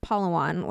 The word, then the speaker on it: Palawan: So for their 18th wedding anniversary, they went to the Dos Palmas Resort on the island of Palawan, which is like a resort town. Ryan is nodding because I pronounced it correctly.